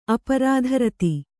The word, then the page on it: ಅಪ್ರತಿರಥ: kannada